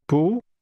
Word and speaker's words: 不: the pu, pronounced with a rising tone, is what makes that negative.